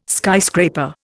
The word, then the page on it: skyscraper: Indian